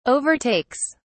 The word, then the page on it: overtakes: British English